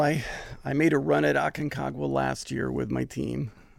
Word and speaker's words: Aconcagua: Later this year or into early next year, you're planning to summit, I'm going to try to say this right, Aconcagua in Argentina? – Aconcagua. – Aconcagua, yes.